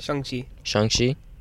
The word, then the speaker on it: Shang-Chi: How do you pronounce it? – Shang-Chi. Yeah.